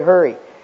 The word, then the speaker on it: Hari: The Hindus worship a god, and it's spelled H-A-R-I. It's pronounced Hurry, the god of hurry.